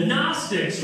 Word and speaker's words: Gnostics: Starts with a silent G. The Gnostics, right?